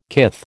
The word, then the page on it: kith: American English